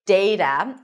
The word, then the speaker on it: data: You can either pronounce it as data, with the A as in day, data, and I am using the general American pronunciation, so you'll notice that my T sounds a little bit like a D. Data, data, data. In British English, you'll hear something closer to data with a soft T.